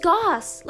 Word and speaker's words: Goss: They would always put on my report cards, let's see, Emily Gross, Emily Gloss, Emily Goose, like Goose, like the name of the animal Goose. And all of this dumbass stuff, and it's just Goss. Like, it's so easy to pronounce, and people just don't get it right, but it's fine.